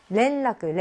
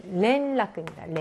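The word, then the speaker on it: 렌라크 (連絡): 연락이 렌라크, 렌라크 이렇기 때문에 렌이, 렌이지, 렌이지 조금 약간 혼란스러울 수 있어요. 네, 렌라크입니다. 렌.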